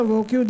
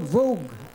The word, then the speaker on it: Vogue: This is not the VOQ, this is a magazine called Vogue. Vogue. In America, we pronounce this as Vogue.